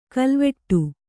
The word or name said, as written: ಖಲ್ವಾಟ